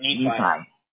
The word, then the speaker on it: Nephi: Yep, Nephi. – All right. Perfect. So one of the things that we do that's rather unique, it's because of our Google partnership, we'll close the Nephi area and a 50-mile radius around your home base for your category of electrical.